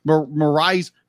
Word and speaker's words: Mirai: Mirai. – Mirai's future self and their whole journey in order to, because me personally be like, I'm watching this first one.